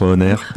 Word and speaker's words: On Air: Ça se prononce comme ça? – Oui, c'est comme ça. Bah, c'est comme la radio, tu sais, On Air, c'est le...